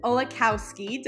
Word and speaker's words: Olakowski: This is our friend Grace Olakowski. Did I say that right? – Yes. Okay. Yep, you did. – Grace, it sounds like Mike Wazowski.